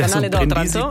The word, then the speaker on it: Canale d'Otranto: Also Sazan liegt auf einem wichtigen Punkt an der Meerenge zwischen Italien und Albanien, also am Canale d'Otranto, wenn ich es richtig ausspreche. – Canale d'Otranto, Brindisi. Ja.